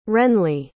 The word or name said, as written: renley